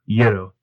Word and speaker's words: gyro: Like, I've heard it. There's gyro, gyro, gyro, gyro. It's like, no, man. Like, only one pronunciation, gyro.